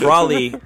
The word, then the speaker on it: Broly: Isn't it Broly? – How dare Broly? You talking about Broly? – Yeah, Broly. So he's fighting against Broly, and he goes Super Saiyan God, but Broly does it too.